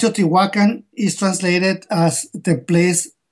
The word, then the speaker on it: Teotihuacan: Is it Teotihuacan? How do you say that down in Mexico? – It's Teotihuacan. – Teotihuacan.